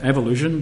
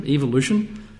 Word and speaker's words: evolution: I think in America they pronounce it evolution, but in Britain it's evolution.